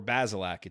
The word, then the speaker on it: Bazelak: And by the way, it is Bazelak. It's 2 syllables. Dustin DePirak confirmed this. It's not Bazelak or Bazelak. It's Bazelak. 2 syllables. Bazelak.